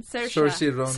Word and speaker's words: Saoirse: Saoirse Ronan. – Saoirse Ronan.